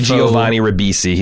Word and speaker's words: Giovanni Ribisi: He's very Giovanni Ribisi.